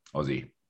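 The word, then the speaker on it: Aussie: It's exactly the same as Ozzy Osbourne. So you say it like an O, O-Z-Z, Ozzy.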